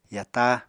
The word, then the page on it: yata: apatani